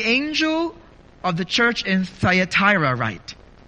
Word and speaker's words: Thyatira: And this church tonight is the church in Thyatira. Other speakers, other theologians will pronounce it Thyatira. So I may go back and forth to how it's pronounced, but you know where exactly we are at.